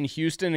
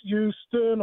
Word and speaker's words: Houston: Houston Oilers, right? – Yeah. – They don't pronounce the H.